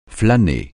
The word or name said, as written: flâner